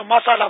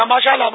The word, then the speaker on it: ماشاء اللہ: اب مشکل ہے کہ ماشاء اللہ کہنا بھی تو نہیں آتا نا، ہمارے یہاں تو مسالہ مسالہ مسالہ یا ماشاءاللہ ماشاءاللہ ماشاءاللہ، اب ماشاءاللہ مسالہ تو مسالے مشالے سے کچھ نہیں ہوگا. ماشاء اللہ یہ تلفظ یاد کر لیں تو انشاءاللہ ماشاء اللہ کہیں گے تو نظر نہیں لگے گی.